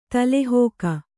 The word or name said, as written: ತಲೆಹೋಕ